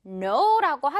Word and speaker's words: know: 먼저 알다라는 이 단어 K로 시작하면서 N 바로 나오기 때문에 K는 유령으로 사라지면서 발음 어떻게 되겠어요? 그쵸? 그냥 No라고 하죠, 우리.